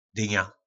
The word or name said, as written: dyna